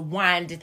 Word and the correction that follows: wine: I know I'm saying it wrong. I know it's bad. Y'all know how to wind it. – Yeah. – Wine, as you explain. – Mm-hmm. You don't need a D in there.